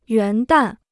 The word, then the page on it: 元旦: yuán dàn